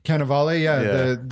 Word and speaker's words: Cannavale: Cannavale, the skinny razor guy.